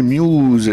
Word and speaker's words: Muse: Muse, prononcé en anglais Muse, est un groupe de rock britannique originaire de Teignmouth dans le Devon en Angleterre.